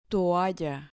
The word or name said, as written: toalla